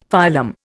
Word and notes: phylum: Indian

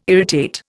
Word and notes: irritate: Indian

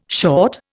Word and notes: short: Indian

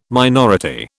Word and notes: minority: British English

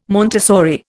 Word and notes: Montessori: Indian

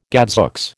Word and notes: gadzooks: American English

